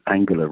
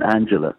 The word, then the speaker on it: Angela: I mean, we had to learn to pronounce her name as Angela rather than Angela, which would be the natural thing to do in English.